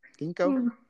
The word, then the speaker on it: ginkgo: So the first one is on ginkgo biloba. Is that, am I pronouncing that right, do you think? Ginkgo? – Ginkgo, yeah.